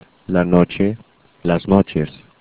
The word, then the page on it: noche: spanish